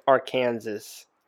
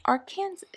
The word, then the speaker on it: Arkansas: She says Arkansas. – That's how it's spelled. It says Ark. And Arkansas.